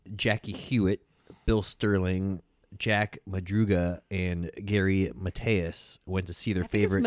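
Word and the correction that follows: Mathias: It's Matthias. – Matthias? – I think so. – He's not like a Swedish guy? – He could be, but I had a Swedish friend named Mateus. – Yeah, I've heard most people pronounce it Matthias.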